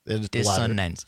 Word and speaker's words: dissonance: I may be wrong, but I'm fairly sure it's cognitive dissidents. Yes. – Dissonance. You still dissidents. Said, it still sounded like you said dissidents the second time.